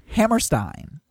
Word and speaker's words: Hammerstein: It is Hammerstein. I know this, first of all, because that's how everyone pronounces it, except for you. And second of all, because a German name such as that, or German-ish, anything with an E-I at the end is I, and anything with an I-E is E.